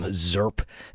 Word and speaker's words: Zirp!: The new album, Zirp, which I think that's how that's pronounced. Z-I-R-P exclamation point. Should I scream it? Dive, D-I-I-V, going to be making their way to the Town Ballroom on the 30th of this month.